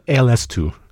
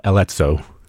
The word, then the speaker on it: Élesztő: It's a Élesztő. Élesztő. And Élesztő, yeah. And if you look at it, it looks like uh, Eletzo and if you say Alezzo, people are probably going to stare at you funnily.